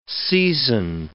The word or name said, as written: season